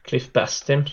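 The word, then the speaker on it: Cliff Bastin: Hur uttalar man hans namn? Jag säger jämt Bastien med fransk accent, men han är ju inte fransman. – Cliff Bastien. – Cliff Bastien, såklart, ja.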